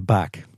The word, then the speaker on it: BAAC: Moeten we dat anders uitspreken? Eh, baak. Mag je het als de Baak zeggen?